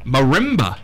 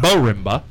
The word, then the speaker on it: marimba: Marimba. – Marimba. – I was mispronouncing it. Yeah. Not Bo-rimba.